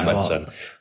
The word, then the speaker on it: Amundsen: Yeah, am I pronouncing it wrong? Yeah, Amundsen.